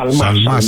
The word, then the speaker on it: Σαλμά: Να πούμε πολύ γρήγορα και για την άρση ασυλίας του κυρίου Σαλμά, έτσι δεν είναι? – Σαλμά, συγγνώμη για το γιώτα που εμφυλοχώρησε στην προφορά μου.